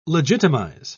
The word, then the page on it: legitimise: British English